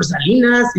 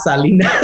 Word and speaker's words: Selena: So anything for Salinas, you know? – Yes. – And I was like, you're not saying her name right. It's not Salinas.